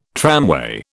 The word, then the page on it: tramway: British English